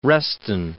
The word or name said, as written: Reston